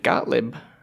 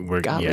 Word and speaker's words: Gottlieb: And Gottlieb, is that how you pronounce that? – Gottlieb.